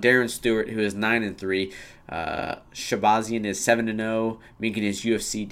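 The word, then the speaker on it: Shahbazyan: Man, you have any idea how to pronounce that? – I just say Shabazian. – Oh, damn, you got it down.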